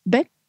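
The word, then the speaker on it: Bích: Well, my given first name, spelled B-I-C-H, has an accent mark over the I, so that it really should be pronounced Bic, you know, but you know, most people just say Bic, like B-I-C.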